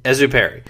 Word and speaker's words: Exupéry: Exupéry.